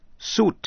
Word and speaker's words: suit: Và quý vị sẽ học chữ suit, suit, S-U-I-T, nghĩa là bộ quần áo. Chữ suit có hai cách đọc, có thể đọc là suit hay là suit. Nghe lại, suit, suit, cũng nên phân biệt chữ S-U-I-T-E là suite, nghĩa là một dãy phòng rộng, S-U-I-T-E, còn suit, S-U-I-T. Mời quý vị nghe câu, I didn't read any comics except Superman.